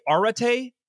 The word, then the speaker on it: Areté: The book is titled, it's spelled A-R-E-T and E with like a hyphen over the top. Is it like karate, arate? – Yeah, dude. Perfect.